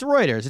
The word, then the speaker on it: Reuters: Well, th- next story of the week comes to us from Re- Roy- how do you say it? Reuters. Reuters. Readers. It's Reuters. It's spelled Reuters.